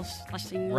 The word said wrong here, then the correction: Scherzinger: I can never say her last name. – Scherzinger. That's not even close to Schlesinger.